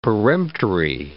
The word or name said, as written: peremptory